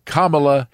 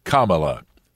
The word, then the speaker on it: Kamala: this is Senator Kamala Harris." That's how you pronounce the name, like C-O-M-M-A hyphen L-A, Kamala.